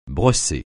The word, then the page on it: brosser: francais